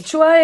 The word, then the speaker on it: SHOA: El sí, el choa es. – Es que el choa. Además, no, yo Chua lo encuentro horrible porque la hueá se escribe S-H-O-A, Servicio Hidro.